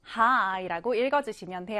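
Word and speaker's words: high: 자, 스펠링 보시면 H-I-G-H인데요. 읽을 때는 high라고 읽어주시면 돼요.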